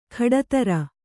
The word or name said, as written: ಖಡತರ